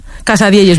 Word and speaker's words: Casadielles: Casadielles, muy bien dicho.